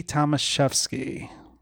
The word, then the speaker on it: Tomaszewski: She's a Pilates wellness studio owner and a health coach, Katie Tomaszewski.